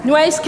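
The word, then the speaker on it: Nueces: Uh, how do you pronounce the name of his county? New, New, Newes County? Hmm? Okay, thank you. Nuez County.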